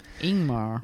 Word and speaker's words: Ingmar: I think you said Ingmar. Is that how you pronounce his name? – Ingmar.